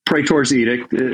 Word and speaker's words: Praetor's Edict: Praetor's Edict. Praetor's Edict is how I pronounce it, so I think that's right.